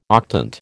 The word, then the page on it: octant: American English